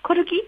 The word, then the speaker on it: コルギ: はい、そして私気になったのが、骨って書いて、天気の木、コルギって読むんですかね、はいはい。